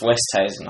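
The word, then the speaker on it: Oosthuizen: It's actually pronounced Westhuizen.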